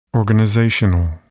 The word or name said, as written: organizational